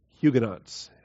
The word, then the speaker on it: Huguenots: How do you pronounce Huguenots? So I found one official dictionary and one encyclopedia that gave the pronunciation as Huguenots. I've heard about 5 other pronunciations over the years, and whether they're right or wrong, I will leave them in the hands of the Lord. I'm going to stick with Huguenots for tonight.